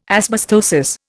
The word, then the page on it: asbestosis: Indian